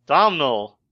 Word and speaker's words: Domhnall: Domhnall.